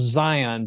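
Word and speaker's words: Zeihan: So it's Peter, and his last name is spelled, it's pronounced Zion, but it's Z-E-I-H-A-N.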